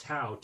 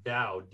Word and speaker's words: Tao: It's not pronounced Tao, T-A-O. It's pronounced Dao, D-A-O.